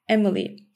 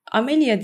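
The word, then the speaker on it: Amélie: Çok meşhur bir film var, Emily. Amelia diye yanlış telaffuz edildiği için böyle e, bu şekilde aklınızda kalmış olabilir.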